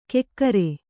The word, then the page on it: ಕೆಕ್ಕರೆ: kekkare